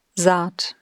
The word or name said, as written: Saat